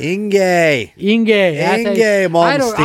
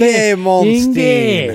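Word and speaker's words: Yngwie Malmsteen: Yigni Malmsteen. – Inge Malmsteen. – I never knew how to say his name. – Inge Malmsteen. Inge.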